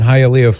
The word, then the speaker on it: Hialeah: Gilberto in Hialeah, Florida.